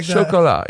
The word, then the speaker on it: Chocolat: Yeah, it's like Chocolat. That. Yeah, you said it right.